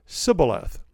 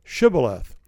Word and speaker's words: shibboleth: So there was a word, shibboleth, that the two groups of people pronounced differently. In Ephraim, they could not pronounce the SH. Okay, it became S. The Gilead guys, they could say shibboleth. The Ephraim guys couldn't. They said sibboleth.